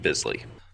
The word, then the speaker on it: Bisley: A bit of IMDb trivia for today, specifically related to Steve Beasley, who I think it's Beasley, not Bisley. Because Bisley sounds a little weird. – Quick note from the corrections department. It's pronounced Steve Bisley.